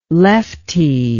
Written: leftie

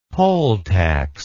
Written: poll tax

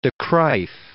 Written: de Kruif

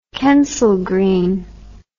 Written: Kensal Green

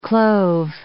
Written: clove

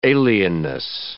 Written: alienness

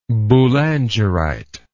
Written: boulangerite

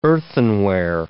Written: earthenware

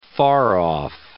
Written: far-off

